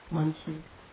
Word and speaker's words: Manjushri: Manjushri. – Manjushri does that. Am I pronouncing that? That's right.